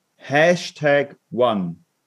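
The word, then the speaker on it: Smart #1: Hashtag One. Ausgeschrieben okay. oder mit dem Hashtag? Nein, nein, das Zeichen und dann Eis. Aber die offizielle Aussprache. Hashtag One.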